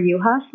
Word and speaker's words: Yuhas: My name is Jennifer Yohas. My last name is spelled Y-U-H-A-S.